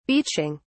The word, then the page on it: beaching: British English